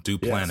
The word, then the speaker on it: Duplantis: Duplantis.